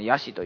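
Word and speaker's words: 香具師: ヤシ、これもね、最近あの 2ちゃんでよく見ますよね、このヤシという言葉、香る、道具の具に、美容師の詩のあれですね、詩ですね、はい、これヤシと読む、はじめ僕工具師やと思ってましたけどね、ヤシと読みますね、香る、道具の具に、師匠の師ですね、はい、これヤシと読む、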